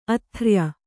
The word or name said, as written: ಆದೊರೆಯ